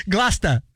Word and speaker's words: Gloucester: Gloucester, what up? – Gloucester. – Oh, that's Gloucester? – Gloucester. – Okay. So if that's Gloucester, then this is Loster.